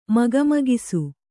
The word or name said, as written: ಮಕಮಕಿಸು